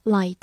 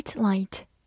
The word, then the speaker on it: light: 字母 i 发的本身的音 i，g h 不发音，light，它是一个名词，表示光、光线、灯。比如说 bright light 就是明亮的光线，bright light。